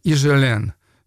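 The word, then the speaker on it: Higelin: Жак Ижелен его зовут, французская фамилия Ижелен — она пишется, там первая буква H, Хижелен пишется, хотя буква эта не читается.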